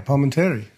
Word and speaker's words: Palminteri: Yeah, Palminteri.